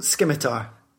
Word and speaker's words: scimitar: So a scimitar. – That's pretty cool. Scimitar. Scimitar. With a hard C.